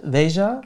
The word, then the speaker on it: Veja: Das heißt, die erste und wichtigste und komplizierteste Frage ist immer, wie spricht man den Markennamen aus? Du hast es ja gerade schon beantwortet. – Veja, genau.